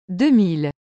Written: 2000

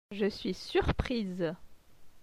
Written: Je suis surprise

